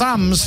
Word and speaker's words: Thames: I think you'll find it's pronounced Thames.